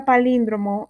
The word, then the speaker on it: palíndromo: Esta palabra palíndromo es una palabra esdrújula, se acentúa en la antepenúltima sílaba, que viene siendo la lin o la i, porque se estresa más en esa sílaba.